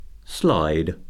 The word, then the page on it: slide: verb